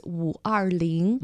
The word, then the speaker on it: 五二零: It's because in Chinese pronunciation, May the 20th is Wu Er Ling. – Mm-hmm. – So it's also similar to the sound of I love you in Chinese.